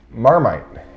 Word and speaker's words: Marmite: how would you pronounce that Marmite.